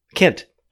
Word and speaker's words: Kent: Personally, I'm a cunt. That is, my ancestors are from the British county of Kent. Kent, sorry.